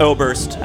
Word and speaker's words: Oberst: Oberst.